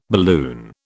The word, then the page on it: balloon: British English